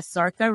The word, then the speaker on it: Zarqa: yes, yeah, Zarqa rhymes with parka.